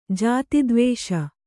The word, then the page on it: ಜಾತಿದ್ವೇಷ: jāti dvēṣa; Kannada